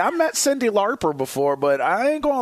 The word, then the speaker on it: Lauper: Lauper.